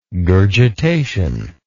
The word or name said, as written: gurgitation